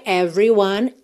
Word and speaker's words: everyone: Atenção que a gente não costuma pronunciar esse E do meio aí dessa palavra, tá? Então, você pensa em every, do V direto pro R. Everyone, one também termina no N, o E final não é pronunciado. It's up to you, it surely can be done. Essa palavra que se escreve S-U-R-E-L-Y se pronuncia surely, surely, ok?